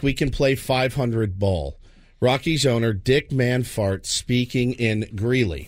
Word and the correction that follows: Monfort: I think it's pronounced Monfort. – What'd I say? – Well, you're confusing the O's in his name with A's. Oh. I think you were replacing a little A with the O.